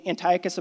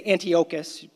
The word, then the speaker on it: Antiochus: Has anybody ever heard of the name Antiochus Epiphanes? Or you could say Antiochus. Scholars pronounce it both ways, Antiochus Epiphanes or Antiochus Epiphanes.